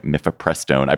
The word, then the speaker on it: mifepristone: And Kaczmarek, as hoped, delivered last week what they hoped for, what they anticipated, issuing an injunction against the medication abortion drug mifepristone. I pronounced it correctly, on the grounds that the FDA supposedly approved it hastily more than 20 years ago.